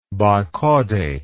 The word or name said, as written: bicaudate